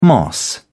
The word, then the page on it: moss: British English